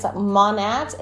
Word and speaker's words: Monat: How do you say that word, right? Actually, it's Monat.